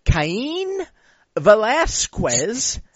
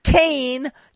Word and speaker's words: Cain Velasquez: On the main card of the UFC 200 pay-per-view, and this is awesome, Travis Brown will take on a man whose name is pronounced Cain Velasquez, not Kane, you fucking racists.